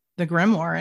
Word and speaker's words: grimoire: It's like W-O-H-R is war. Grimoire. War, W-H-R is kind of the pronunciation.